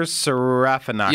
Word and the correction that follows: Serafinowicz: And I'm totally saying his name wrong. – Pete. Yeah, well, it's better than I would have done. – That's right. – I would say Serafinowicz.